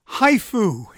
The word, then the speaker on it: HIFU: HIFU, and that is spelled H-I-F-U, which stands for High Intensity Focused Ultrasound, is a procedure that is targeted or a focal tumor treatment.